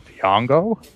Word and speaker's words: Django: Before that, people would be like, what, Django? – The D is silent. – D is silent, indeed.